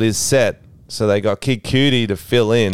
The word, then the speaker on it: Cudi: I meant like cutie, like C-U-D-I. Okay, Kid Cudi filled in.